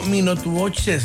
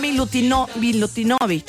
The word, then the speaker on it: Milutinovic: Estoy diciendo Minotuboche, ¿sabes bien? Minotuno. – Es que así no se pronuncia. Así se escribe. – Mino. – Ahí te voy. Bora Milutinovic.